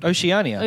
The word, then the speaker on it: Oceania: Oceania.